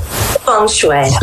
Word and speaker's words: Feng Shui: Is it Feng Shui? How do you pronounce it? – Feng Shui. – Okay, because I see F-E.